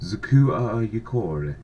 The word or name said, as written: Scotogrelic